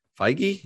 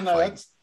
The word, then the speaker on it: Feige: No, Feige? That's Marvel. – Who am I thinking? What's his name? – You're talking about, oh my God, now it's out of my head because you said it wrong.